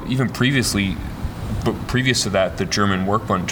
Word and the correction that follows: Werkbund: tradition, school, is that more correct? – Yeah, because it's hard to pronounce, it's the Deutsche Werkbund,